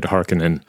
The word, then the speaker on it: Harkonnen: Herbert said Atreides, and he said Harkonnen.